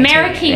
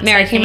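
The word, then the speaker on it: Meraki: It's spelled M-E-R-A-K-I-M-A-T. – Meriki mats. – Meriki mats, yeah.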